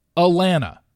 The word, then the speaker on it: Alana: However, I'm such a screw up, and I even, in my notes, in front of me, phonetically spelled out Alana. I did UH-LAN, all caps, dash UH. Alana. Yet, when you see the name A-L-A-N-A, it can be said three ways. Alana, Elena, or Alana.